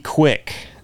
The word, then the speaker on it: Quiche: It's Q U E B E E. So Q, I think that's how you pronounce it. QB Quick. – No way. But the last name is Q U I C H E. So maybe I'm pronouncing that wrong, wrong too.